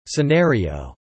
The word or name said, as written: scenario